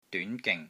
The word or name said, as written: DYUN2 GING3